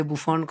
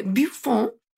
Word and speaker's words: Buffon: Me da mucha risa que mi maestra de historia y filosofía de la biología en la carrera, primer semestre, insistía muchísimo en pronunciar el nombre de Buffon correctamente. Entonces, cada vez que hablaba de Buffon decía Buffon. Y era como, señora, puede decir Buffon, no pasa nada.